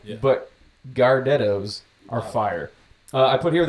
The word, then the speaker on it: Gardettos: It is the Gordettos, but I'm pretty sure they're pronounced Gardettos.